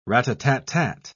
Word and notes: rat-a-tat-tat: British English

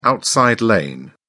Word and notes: outside lane: British English

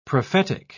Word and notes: prophetical: British English

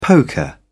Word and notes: poker: British English

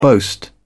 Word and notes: boast: British English

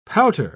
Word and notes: pouter: British English

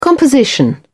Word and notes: composition: British English

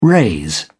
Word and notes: raise: British English

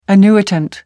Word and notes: annuitant: American English

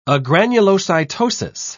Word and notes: agranulocytosis: British English

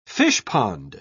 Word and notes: fishpond: British English

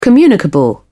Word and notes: communicable: British English

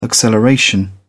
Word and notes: acceleration: British English

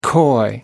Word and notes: coy: American English

